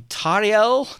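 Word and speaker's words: Tauriel: We see Tariel, because I think that's how we are supposed to pronounce it now, fighting the spiders.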